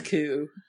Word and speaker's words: Ku: Or the Ku Klux, if you say it correctly. – Oh, it is Ku, isn't it? – It's Ku. It's Ku.